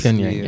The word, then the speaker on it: Pyongyang: That's basically, how do you say that? Pyongyang's. – Pyongyang, yeah.